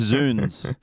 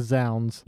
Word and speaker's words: zounds: Zoons. – Zoons. – Because it's God's wounds. – Mm. – Oh, is that zoons. – What that means? – But Ned does pronounce it as zounds.